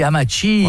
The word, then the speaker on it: Amatxi: A-M-A-T-X-I se dit Amachi, voilà. Et pas Amachi.